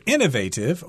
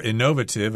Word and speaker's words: innovative: And if you're innovative or innovative, I think you can pronounce it both ways, that means you are someone who comes up with lots of new ideas.